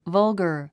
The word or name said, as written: vulgar